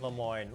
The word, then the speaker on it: Lemoyne: Lemoyne? Lemoyne. It's like Des Moines. It's very easy. – It's Lemoyne.